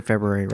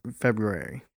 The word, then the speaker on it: February: Feb- February. But I think it's actually pronounced February. February. February? but there's an R there, so it's like February.